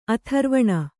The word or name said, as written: ಆಥರ್ವಣ